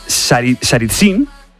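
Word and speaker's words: Saritsyn (Царицын): Por ejemplo, Stalin convirtió, a ver si lo digo bien, Saritsyn, bien,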